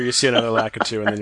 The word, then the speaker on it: Lakitu: You'd pronounce the K-I as Ki if it was Japanese, so I'm presuming you borrow it from that, but my American tongue wants to say Lakitu.